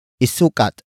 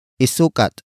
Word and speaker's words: isukat: Word for word, it's isukat mo ito. Isukat mo ito. Isukat mo ito. The first word is isukat. Isukat. Isukat. Isukat is spelled as I-S-U-K-A-T.